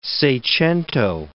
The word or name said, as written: seicento